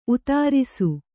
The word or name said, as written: ಉತ್ತರಸ